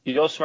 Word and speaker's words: Jos: Ee, babası nasıl okunuyor? Jos diye mi okunuyor? Jos, Verstappen.